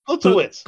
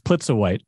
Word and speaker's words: Plutsiewicz: She's into like real estate, but she's friends with Plusenheit? – Plutsiewicz. – Plutsiewicz. – You're right. Yeah. – Plutsiewicz. It's easy to pronounce. – I keep wanting to put the U first, but it's Plutsiewicz.